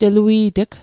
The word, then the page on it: telui't'g: Mi'gmaq